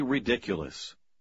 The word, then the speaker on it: ridiculous: В последнем слове вы ставите ударение на второй слог. – Don't be ridiculous. Ridiculous. – 7. – The mixture was too viscous. Mixture. – Как и сочетание T-U-R-E, S-U-R-E, также произносится не так, как читается.